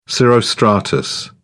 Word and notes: cirrostratus: British English

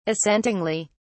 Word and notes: assentingly: British English

